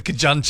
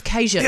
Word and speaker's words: Cajun: My mate Lindsay said he asked for the Kajun chicken. – Kajun. – I was like, Kajun? – Instead of Cajun.